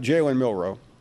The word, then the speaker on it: Milroe: If Milroe is the starter, am I saying that right? Is it Milroe? – Jalen Milroe.